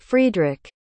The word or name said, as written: friedrich